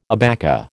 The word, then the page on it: abaca: American English